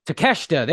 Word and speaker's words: Takeshita: Well, no, Don is the storyline with Don and Takeshita has been happening for a while. Am I saying his name right? I constantly fuck it up. – Takeshita. – There we go.